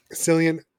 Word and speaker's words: Cillian: But I like Cillian Murphy or Killian Murphy, however you say his name. – Cillian.